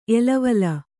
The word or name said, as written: ಎಲವಲ